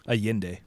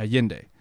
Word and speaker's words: Allende: I think that I'm pronouncing that. What? – Allende. – Allende? Is that how you pronounce it? – Mm-hmm. – Allende.